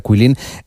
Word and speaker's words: Guilin: Bueno, esta ciudad de Quilin, por si alguien la busca en internet, se escribe G-U-I-L-I-N, como si fuera Quilin, pero se pronuncia Quilin.